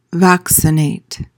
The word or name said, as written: vaccinate